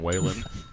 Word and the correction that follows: Wallen: Is that not how you say it? – Wallen. – Wallen.